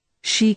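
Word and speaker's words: Xi: Nien Bobby Bui, a National Security Council staffer, now assigned to Derheer, leaned over and said, "Shi Kemu's his name. Spelled X-I. Pronounced Shi.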